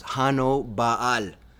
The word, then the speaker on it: Hannibal: And we call him Hannibal today, but we think the name was probably pronounced Hano Baal, meaning John Zeus.